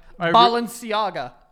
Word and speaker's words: Balenciaga: Balenciaga.